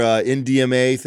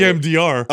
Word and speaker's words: EMDR: Because I know you just did your NDMA thing or whatever. – Wow, you said it wrong. – What is it? – EMDR.